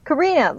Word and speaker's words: Corrina: Corrina, like the song. Actually, I'm named after the song.